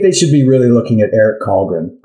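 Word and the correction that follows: Källgren: I'm mispronouncing his last name. – Schalgren. – Thank you. Schalgren.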